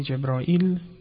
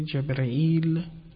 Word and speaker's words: جبرئیل: در تلفظ گاهی جبرائیل گفته میشه، گاهی جبرئیل، حتی گاه جبریل، گاه جبرن، اصلا با نون آخر بدون لام هم گفته میشه.